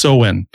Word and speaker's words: Samhain: Halloween's origins date back to the ancient Celtic festival of it's spelled Samhain. It looks like Samhain, but it's pronounced Samhain.